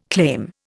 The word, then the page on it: claim: Indian